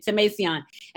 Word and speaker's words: Tomasian: I was saying Tomasian. And she was like, hold on. It's Tomasian.